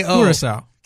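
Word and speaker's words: Curaçao: Is it C-U-R-A-C-A-O? – Curacao. – Curacao, okay.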